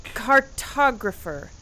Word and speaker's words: cartographer: I knew when I hired a cartrophogic... – Cartographer. If you can't pronounce it, Grimble, mapper will do.